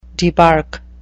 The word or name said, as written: debark